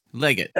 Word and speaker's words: Legut: Leggett. – L-E-G-U-T. – L-E-G-U-T.